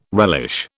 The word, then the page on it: relish: British English